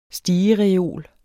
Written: STIGEREOLEN

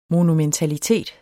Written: monumentalitet

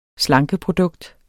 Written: slankeprodukt

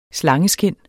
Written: slangeskind

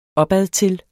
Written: opadtil

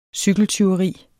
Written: cykeltyveri

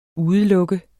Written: UDELUKKES

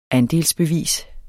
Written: andelsbevis

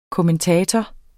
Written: KOMMENTATORER

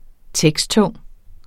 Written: teksttung

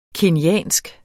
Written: kenyansk

